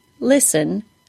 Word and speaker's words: listen: Today's topic is the silent T in words like listen and whistle. That's right, those T's are absolutely silent. Don't say them. There are two different spelling patterns here to be aware of. The S-T-E-N pattern, as in the word listen, and the S-T-L-E pattern, as in the word whistle.